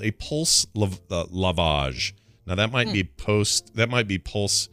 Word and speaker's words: lavage: Lavage.